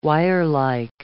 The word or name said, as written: wirelike